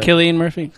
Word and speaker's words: Cillian: How do you pronounce his name? Cillian Murphy? – Killian Murphy. – Apologies. Yeah. Killian Murphy.